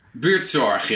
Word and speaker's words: Buurtzorg: How do you say that? Buurtzorg. – Buurtzorg, yeah. The g is very important.